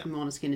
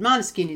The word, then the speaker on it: Måneskin: Måneskin is actually the Danish pronunciation, but Måneskin is the Italian pronunciation.